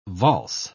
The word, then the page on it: valse: British English